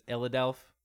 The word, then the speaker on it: Illadelph: Illadelph Half-Life, is that right, Tyler? Is the pronunciation is Illadelph? – Mm-hmm. – So I thought.